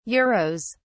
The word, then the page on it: euros: British English